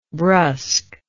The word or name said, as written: brusqueness